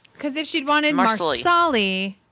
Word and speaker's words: Marsali: And here's more evidence that at least in the book, it's three syllables to Marsali. Diana spells it out with dashes. MARSALI. If it's Marsley, you don't pronounce the A, or it's kind of swallowed.